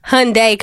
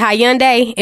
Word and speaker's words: Hyundai: Okay, of course, the Honda commercial or the Hyundai commercial. I always pronounce it Hyundai, and my mom was like, that is not how you pronounce it. – Hyundai.